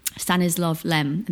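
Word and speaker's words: Stanisław Lem: Stanislav Lem, and that's how you say it, the Russian sci-fi novelist, nice.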